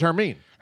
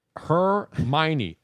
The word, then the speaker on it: Hermione: If you spell the word out, you don't realize it's pronounced Hermione. – I thought it was Hermine. – Hermione. Okay. Hermione.